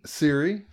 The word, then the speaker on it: Ciri: Is it Ciri? – Ciri, his sort of daughter.